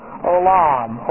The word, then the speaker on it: olam: And we have, this is silent, the first radical. This is an O sound. And then we have L, and then M. These are the three major radicals. Olam, O-L-A-M, olam.